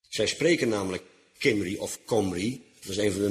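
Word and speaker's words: Cymry: Maar ook in onze dagen zijn de Welsh, dat wil zeggen de inwoners van Wales, los de enigen nog die als Kimri, uitgesproken als Comri, worden aangeduid.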